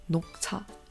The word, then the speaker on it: 녹차: In Korean, we call this 녹차.